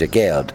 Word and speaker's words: Aguerd: The pronunciation of the name is Aguerd.